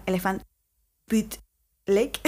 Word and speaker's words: Elephant Butte Lake: Elephant Bad Lake. No se dice se dice, perdón, Elephant Butte Lake, que es una zona adyacente a TIC.